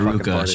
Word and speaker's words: RVCA: What's the correct way to say Ruka.